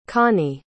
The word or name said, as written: conny